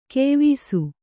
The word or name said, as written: ಕೆಯ್ವೀಸು